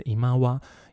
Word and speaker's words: 今際: 就是天际线的际，然后它的日文发音是伊妈哇，伊妈哇的意思哦，就是林中弥留的意思，所以在就是漫画里面，很多人会翻成弥留之国啦。